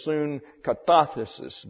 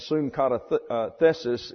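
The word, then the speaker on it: sunkatathesis: That's S-U-N-K-A-T-A-T-H-E-S-I-S. The accent's there, it's soon katathesis, but we have a tendency to want to go to the second syllable back from the end, and soon katathesis is the one that's easiest for us to say. But it really is soon katathesis, which breaks your tongue.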